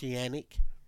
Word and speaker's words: Canik: The TP9 is a world-famous gun by Canik, but it's pronounced Yannick. but it says C.